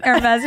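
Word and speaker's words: Hermès: Okay, we are going to Hermes Hell today. Did I say it right? – Hermes, yeah.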